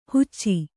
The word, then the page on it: ಹುಚ್ಚಿ: Kannada